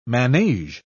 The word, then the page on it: manege: British English